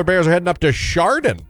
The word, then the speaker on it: Chardon: It's Chardon, I promise.